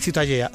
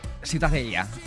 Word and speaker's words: Ciutadella: ¿cómo se pronuncia? – La catalán? Ciutadella. – Ciutadella. Bueno, pues Ciudadela también en castellano.